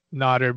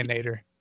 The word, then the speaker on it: Nader: I've made a decision that it's Nutter because I found a clip of him saying his name speaking Arabic, and he said Abdul Nutter. And I believe that it's something that he has changed the pronunciation of so Americans can get it right by letting them say Nader.